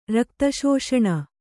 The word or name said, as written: ರಕ್ತಶೋಷಣ